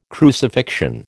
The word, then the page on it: crucifixion: American English